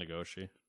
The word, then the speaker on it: Nagoshi: So as Nagashi, however you say his last name, probably butchering it, sorry. – Probably Nagoshi. That's very American of me.